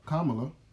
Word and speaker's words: Kamala: Okay, okay, so he's the elect, and then Kamala. – Yes, I said her name right.